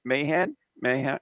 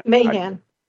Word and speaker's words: Mayhan: And Mayhan? – Mayhan. Mayhan. Yep, Mayhan.